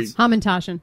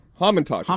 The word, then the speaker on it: Hamantaschen: Hamantaschen. It's a Hamantaschen. – Hamantaschen.